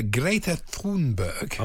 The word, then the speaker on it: Greta Thunberg: Greta Thunberg. – Oh, yes, beautifully pronounced.